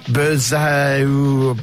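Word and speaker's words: Berzatto: Kami Berzato. – Correct pronunciation.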